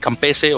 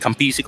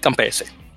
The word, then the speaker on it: Campese: Eh, uno de los grandes, eh, eh, David, Campese o David Campisi, como lo pronunciarían los, los australianos, pero Campese tiene ese italiana, así que David Campese.